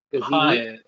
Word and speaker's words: Piett: P-I-E-T-T? – Piet. – Piet. Is it Ozel, Nida, or Mati? – Mati. – Nida. Piet.